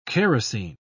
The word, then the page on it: kerosine: British English